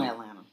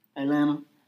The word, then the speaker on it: Atlanta: First of all, we don't even pronounce the T's in there. – We do. – Atlanta. Even that was too much.